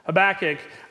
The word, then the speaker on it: Habakkuk: Habakkuk, I asked him how he wanted his name pronounced, and that's how he said, so that's what I'm going with.